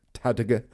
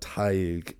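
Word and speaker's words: Tadhg: You'd just think Tad, Tad, Tadiga. But Taig is how it is pronounced.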